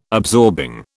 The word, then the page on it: absorbing: British English